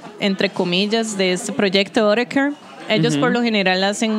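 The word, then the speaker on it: Autechre: Y la segunda canción que acabamos de escuchar es de Otterker. ¿Así lo pronunciaríamos? – Sí. – Autecre. – Sí, Autecre.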